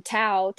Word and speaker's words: Tao: Like, I, I originally had the last name Tao, T A O, like the dynasty, like the way. Um, so, or like the Americanized pronunciation of Dao.